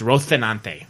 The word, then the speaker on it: Rocinante: Well, that thing is, the original story of Don Quixote was written in Spain, so technically it should be pronounced with that accent. So Rocinante is correctly pronounced Rocinante, with a TH with that list, Rocinante.